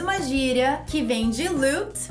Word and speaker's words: loot: E o que, que é lute? Lute não é lutar. Lute, na verdade, a pronúncia correta é loot. Repeat after me loot.